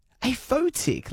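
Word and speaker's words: aphotic: A photic. Let me spell it for you. A-P-H-O-T-I-C. I was thinking, kind of going off the tick sound and then the photo.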